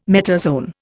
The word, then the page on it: metazoan: Indian